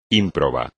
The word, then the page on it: ímprobo: espagnol